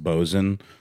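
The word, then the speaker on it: boatswain: Wouldn't it be funny if the word quartermaster was actually pronounced something like quarmster, you know, sort of the way boatswains pronounced bosun, one of these inexplicable nautical terms that just does not read at all on paper the way it's pronounced? – Or Worcester?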